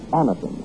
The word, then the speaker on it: Anacin: Remember the name, Anacin. It's spelled A-N-A-C-I-N. Anacin.